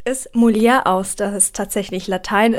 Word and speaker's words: Mulier: Man spricht es nicht Französisch aus, aber ich habe jetzt schon alles fast gehört, wie man es ausspricht. Aber offiziell spricht man es MULIER aus, das einfach nur auf Latein Frau bedeutet.